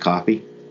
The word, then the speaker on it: Coffey: And it's definitely, even though it's spelled with a Y and not an E, it's pronounced Coffey? – It's pronounced Coffey, I'm sure of it.